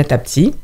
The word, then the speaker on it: Thabti: Vous avez une page sur Facebook, donc elle est à mon nom, elle est au nom de Sonia Tapti. Donc, ils verront, de toute façon, j'ai... – Donc, il s'écrit T-H-A-B-T-I. – T-H-A-B-T-I, oui.